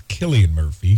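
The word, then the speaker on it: Cillian Murphy: Well, the Killian Murphy, that's how you say his name. I learned that. It's Killian Murphy.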